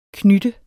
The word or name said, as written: knytte